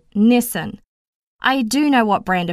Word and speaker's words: Nissan: Having lived in the Greater Toronto area since 2009 and listened to all of the local radio ads, I assure you I am more than aware that you pronounce it as Nissan.